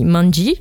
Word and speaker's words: Manji: Donc, euh, d'ailleurs, ça se lit manji,